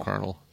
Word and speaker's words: Colonel: Colonel. Spelled with a K? – Yeah. – Yeah, right. – Little Colonels. – A corn kernel.